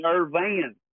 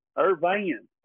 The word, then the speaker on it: Irvan: No, that's Ernie Irvan. Irvan. – Oops. Oh, yeah, they don't know how to say his name yet. It's Ernie Irvan.